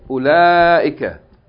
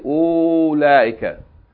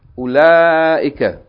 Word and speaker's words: أولئك: Ula ika. That's how it's pronounced. Okay, so the hamza with dhamma in the beginning is pronounced. But the wow after it is not pronounced. It's not elongating the dhamma. Don't say ula ika. It's u short. Ula ika.